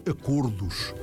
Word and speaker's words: acordos: A palavra acordos pronuncia-se, sim, com O fechado.